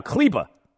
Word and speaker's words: Kleber: Maxi Kleber is somehow Kleba.